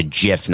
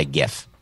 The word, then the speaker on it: GIF: I just learned that it's a GIF, not a GIF. – Yeah, well, either is fine. And people don't want to hear that. The creator says it's pronounced GIF, but you can pronounce it GIF.